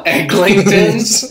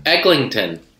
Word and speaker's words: Eglinton: He said, you know, people are from Toronto when they pronounce the silent second G in Eglinton. There is no second G. – Yeah, you have to not be from there if you say Eglinton.